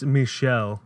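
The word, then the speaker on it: Michiel: Mikhail Hoisman. – It's Michelle.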